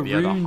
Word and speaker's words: Rune: It's Holger Rune, George. The E is silent.